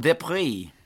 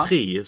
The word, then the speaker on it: Depré: Där lyssnade vi innan matchen i lördags, jag och Lena Sundqvist, just hur han uttalade sitt namn. Så att Depré är det, ska det vara det rätta. Men är det någon som tycker att vi säger fel, så får ni gärna skicka mail.